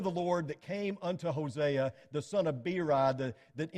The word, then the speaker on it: Hosea: By the way, you ever heard his name pronounced Hosea? I didn't hear that until I got to college. And apparently that is the proper way to pronounce it. So if I say Hosea instead of Hosea, you know what I'm talking about.